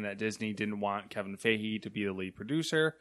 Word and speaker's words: Feige: leaving Marvel Studios and producer Kevin Feige. Is that how you say his name? I think it's Feige. – Yeah. That's how I would say it.